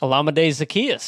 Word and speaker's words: Olamide Zaccheaus: Olamide Zaccheaus? – Olamide Zaccheaus.